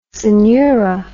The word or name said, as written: synura